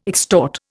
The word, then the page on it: extort: Indian